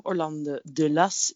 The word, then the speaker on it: Orlando di Lasso: Van Orlando di Lasso. Orlando de Las, zeiden we net ook al.